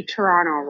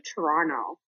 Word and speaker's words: Toronto: I'm a Canadian, and I have to say, you say Toronto wrong. You guys always say Toronto. We, Canada, we say Toronto. Thanks. Bye. – All right. Noted. – A silent T is what I'm hearing. Toronto.